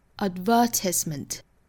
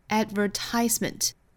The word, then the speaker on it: advertisement: 比如说我们小的时候都学过广告这个词，那英音的话呢会读成 advertisement advertisement，那美音的话是 advertisement advertisement，那当然重音的位置是不同，所以整体单词的发音就会有不同了。